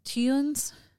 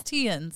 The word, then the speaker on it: Theuns: They say it Tienz. – Okay. – I can't say it. – Tienz? – Tienz. – Tienz. – It's spelled T-H-E-U-N-S. – Okay. – So we're going to go with Tienz. Tienz. I've heard it with the T-H sound as well, but.